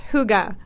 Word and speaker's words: hygge: It's pronounced hygge, but I did not think that was how it was pronounced. H-Y-G-G-E.